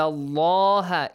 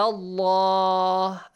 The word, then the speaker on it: Allah: Illa Allah. At the same time, the letter lam in the word Allah is pronounced with a heavy sound, ah yeah.